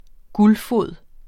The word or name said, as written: guldfod